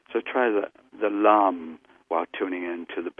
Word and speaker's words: lam: The traditional Sanskrit sound for the root chakra is lam, L-A-M, lam. It's really nice to enunciate the L sound a lot.